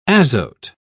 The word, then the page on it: azote: British English